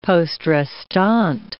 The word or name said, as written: poste restante